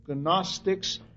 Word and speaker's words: Gnostics: They were Gnosticizing heretics, and fruits of the Gnostics, G N O S T I C S, Gnostics. Uh, in English, the G does not get pronounced, so we pronounce it Gnostics or Gnosticism.